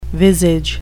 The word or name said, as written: visage